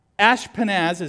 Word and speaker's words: Ashpenaz: It says, then the king ordered, you can pronounce this different ways, but Ashpenaz is really how most people say it, is the chief of staff.